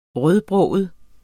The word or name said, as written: rødbroget